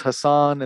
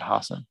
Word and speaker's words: Hasan: Ooh, I used to think it was Hassan, and then I actually heard him say it, and he's pronounced it Hasan.